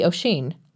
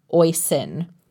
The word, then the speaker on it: Oisín: When we went to the park, we met up with them, and I said, "This must be Oisín." Liam asked me why I said it like that. I asked what he meant. He said it's pronounced like Oisín, like hoisin sauce without the H.